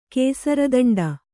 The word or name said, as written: ಕೇಸರದಂಡ